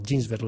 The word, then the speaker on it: Ginsberg: Él decía que él pudo haberse sumado a la generación Beat porque la vivió, era amigo de Gregory Corso, Allen Ginsberg, o Ginsberg, ¿lo he dicho bien? – Sí, sí.